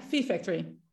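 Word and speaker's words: PH Factory: Is het V-Factory, is het? – Ja, Fee Factory. – En ik zou um, pie zeggen of iets dergelijks, maar goed. – Ja, in het Engels is het pie.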